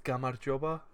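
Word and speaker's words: Gamarjoba (გამარჯობა): Gamajoba. Nailed it. Good luck pronouncing that one. Wait, hang on. We need to be back on me. Good luck pronouncing that one. It's pronounced Gamarjoba.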